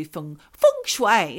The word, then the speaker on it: feng shui: Like you also do feng shui.